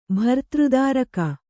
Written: ಭರ್ತೃದಾರಿಕೆ

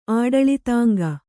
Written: ಆಡಳಿತಾಂಗ

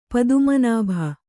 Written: ಪದ್ಮನಾಭ

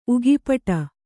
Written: ಉಗಿಪಟ

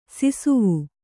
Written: ಸಿಸವೆ

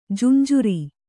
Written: ಜಂಝಾರ

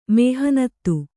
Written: ಮೆಹನತು

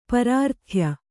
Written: ಪರಾರ್ಥ್ಯ

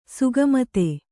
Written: ಸುಗಮತೆ

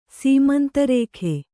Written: ಸೀಮಂತರೇಖೆ

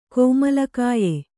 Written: ಕೋಮಲಕಾಯ

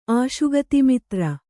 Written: ಆಶುಗತಿಮಿತ್ರ

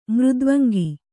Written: ಮೃದ್ವಂಗಿ

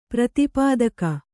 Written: ಪ್ರತಿಪಾದಕ

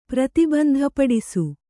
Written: ಪ್ರತಿಬಂಧಪಡಿಸು

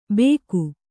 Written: ಬೇಗು

